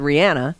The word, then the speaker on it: Rihanna: Rihanna.